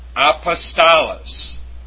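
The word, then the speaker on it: Apostolos (ἀπόστολος): Apostolos. It's not apostolos. It's apostolos. It's not apostolos. It's apostolos. The accent is on the first omicron, and the omicrons are not omegas.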